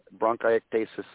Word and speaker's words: bronchiectasis: So bronchiectasis, did I pronounce that correctly? – Yeah, bronchiectasis.